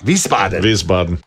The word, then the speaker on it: Wiesbaden: It's in Wiesbaden, Germany. I think I said that right. Wiesbaden.